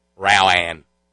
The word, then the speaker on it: Rowan: He represents her and been representing her, and he still doesn't figure it, doesn't know how to pronounce where she's from. Rowan. Rowan County.